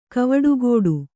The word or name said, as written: ಕವಡೆಗಟ್ಟು